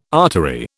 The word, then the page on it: artery: British English